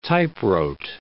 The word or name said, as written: typewrote